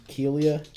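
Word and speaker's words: Kealia: It's like Kalia Ojai. It's Kelia. – Kelia? I think it's sorry. I think that's Kelia.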